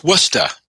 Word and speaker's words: Worcester: Did I say that right? Worcester? – It doesn't have an R. It's Worcester.